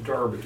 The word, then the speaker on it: Derby: It's actually pronounced Derby. Derby, England. – Derby. Shirley pronounced it Derby, but that's the way it's spelled, but it's really the Derby.